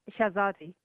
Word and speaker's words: Shahzadi: It's Shahzadi.